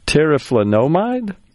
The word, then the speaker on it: teriflunomide: You have to help me with the pronunciation, but teriflunomide? – That sounds good. We'll leave it at that.